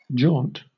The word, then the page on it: jaunt: British English